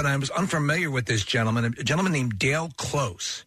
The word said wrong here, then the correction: Del Close: They say his name wrong. Del Close. Yes.